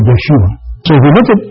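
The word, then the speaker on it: Yeshua (ישוע): In Hebrew, you pronounce it Yeshua or Yehoshua.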